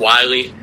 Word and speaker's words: Wilaj: It's actually pronounced Wiley. Doesn't look like it one bit. silent J.